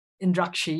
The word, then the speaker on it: Indrakshi: Indrakshi, yeah.